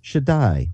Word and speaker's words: Shaddai: Well, this word translated Almighty is pronounced Shaddai, which means Almighty, Most Powerful, and the Almighty.